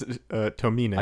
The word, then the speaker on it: Tomine: You got called out for it. It's Tomine.